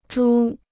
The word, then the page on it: 株: zhū